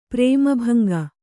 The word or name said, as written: ಪ್ರೇಮಭಂಗ